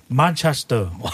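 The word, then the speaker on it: Manchester: 맨체스터를 영국식 발음으로 하면 어떻게 해요? – 만체스터.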